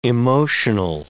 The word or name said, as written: emotional